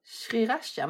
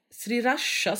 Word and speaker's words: sriracha: Sriracha? Men det är för att jag säger R och du säger R. – Nej, sriracha säger du.